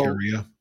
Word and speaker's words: Valkyria: Valkyria.